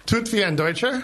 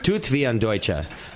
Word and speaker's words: Tut wie ein Deutscher: You might have to help me pronounce this one. The last two are in German. Tut's wie ein Deutsche? – Tut wie ein Deutscher. – Tut wie ein Deutscher. Do it like a German, right? – Yep.